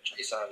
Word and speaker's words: Chaisson: Chaisson, as I say. – Chaisson, yeah.